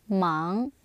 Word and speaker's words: 忙: How would you say busy? – Mang. – Yes. Mang.